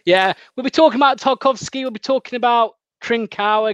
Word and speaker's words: Tarkowski: Just, I mean, briefly, there's things like Tawowski, I can never say it right, Tarkowski. – Tarkowski from Burnley for 50 million.